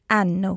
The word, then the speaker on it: hanno: O, ai, a, abbiamo, avete, hanno. – Okay, very good. Just one thing. In Italian, we don't pronounce H. – Of course. – It's mute. So instead of hanno, it would be hanno.